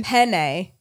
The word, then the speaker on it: penne: You're saying it like P-I-N-A-Y. – Oh, right. Because penne. E-N. Okay, people in Texas, like it's like Ben or a pen.